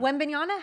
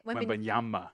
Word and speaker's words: Wembanyama: Am I saying it wrong? – You've been saying it wrong the whole time. – Wembanyama? How do you say it? – Wembanyama.